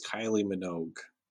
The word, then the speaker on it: Minogue: And thought it was Kylie Minogue, which rhymes with Vogue, but it's not even close.